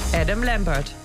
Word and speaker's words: Adam Lambert: Adam Lambert.